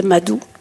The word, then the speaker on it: Madhu: On lui a laissé son prénom qu'elle avait en Inde, Madou. Puis ça s'écrit d'ailleurs pas Madou, comme on dit, comme ça, c'est M-A-D-H-U.